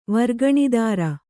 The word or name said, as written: ವರ್ಗಣಿದಾರ